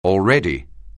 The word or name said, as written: already